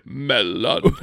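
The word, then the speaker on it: mellon: How do you say friend in Elvish? – Melon. Like a cantaloupe.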